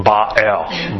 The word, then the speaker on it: Baal: Okay, and the point is, the word is El, Ba-El, Ba-El.